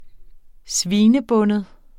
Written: svinebinde

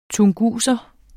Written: tunguser

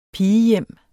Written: pigehjem

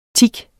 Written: tik

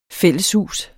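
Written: FÆLLESHUS

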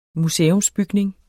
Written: museumsbygning

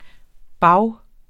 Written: bage

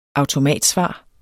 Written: automatsvar

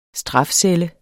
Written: strafcelle

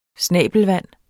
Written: snabelvand

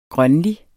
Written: grønlig

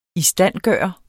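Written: ISTANDGØRE

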